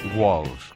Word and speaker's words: Walsh: Walsh, κάπως έτσι προφέρεται μεταξύ O και A, αλλά τέλος πάντων.